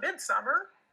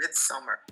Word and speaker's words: Midsommar: Everyone makes fun of me for saying Midsommar. And like, that's how it's spelled. It's not Midsommar. – I've heard it both ways. So I've only heard of Midsommar.